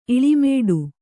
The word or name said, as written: ಇಳಿಮೇಡು